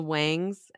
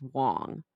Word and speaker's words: Wang: And you know, she calls them the Wangs, and she's like, Mrs. Wang, when really their name, it's spelled W-A-N-G, but it's pronounced Wong.